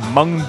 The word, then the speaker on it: Mwng: They also hold the record for the biggest-selling Welsh-language album of all time with their fourth full-length, spelled MWNG, which, as far as I can tell, is pronounced like in mung bean, but it's hard to tell because Welsh is a language where the letter W is a vowel.